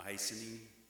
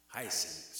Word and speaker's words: hyacinth: I asked my wife for the pronunciation again, that then. The hyacinth? Hyacinth. Say it with me. Hyacinth. Hyacinth. Hyacinth. It's spelled H-Y-A-C-I-N-T-H.